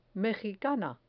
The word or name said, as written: mejicana